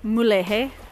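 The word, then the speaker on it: Mulegé: Mulege.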